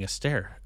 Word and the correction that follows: Astarte: a start. Sorry, I've been butchering that the whole time a, a start.